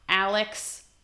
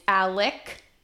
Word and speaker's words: Alec: it is not alex murdaugh the name, the, the way the name is spelled is M U R D A U G H, and the main guy, his name is Alex, A L E X, but that's not how you pronounce it. It is Alec or Alec Murdaugh.